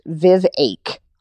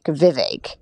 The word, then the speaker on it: Vivek: But their hearts were with Vivek Ramaswamy, who, by the way, his name is pronounced Vivake, like a heartache, Vivek.